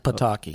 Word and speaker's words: Pataki: The last three governors, in fact, I think it's been like 16 years since Psaki. Is that how you pronounce his name? – Mm. – Psaki was the governor? – Pataki. – Pataki, that's it.